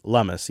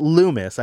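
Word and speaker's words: Lummis: He says, you know, hummus, that's how you say lummus. Yes, not Loomis.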